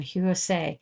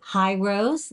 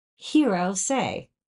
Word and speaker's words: Hirose: Is it Hirose? The answer is no. It's Hirose. Just think of David Bowie.